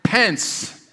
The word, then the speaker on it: Pence: Pence, what did I say? Like, when do we get a new vice president? Vice President Pence. I even have it written as Pence, but I don't know.